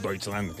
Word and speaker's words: Deutschland: Deutschland, ell dit, però és Deutschland, clar.